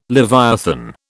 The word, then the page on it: leviathan: British English